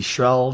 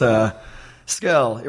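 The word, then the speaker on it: Scurll: I think Jim Ross actually said Marty Schrull the other night, which was a new one for me. Marty Skrull, Marty, all sorts. Yeah, it's... Skrull. It rhymes with, you know, girl, curl.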